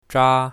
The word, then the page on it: zha1: mandarin